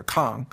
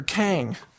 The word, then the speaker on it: Kang: Dr. Kang Formulas, in Chinese, of course, it's pronounced Dr. Kong, but I'm Americanizing it for you. Dr. Kang.